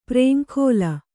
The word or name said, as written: ಪ್ರೇಂಖೋಲ